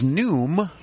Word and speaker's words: Noom: Her new favorite app is Noom. That's how you say it? Like yes. – Noon with an M.